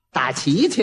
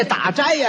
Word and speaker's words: 打斋: 打旗去呢，他也错了一个，这打斋呀，他给念成打旗了，斋字他念成旗了。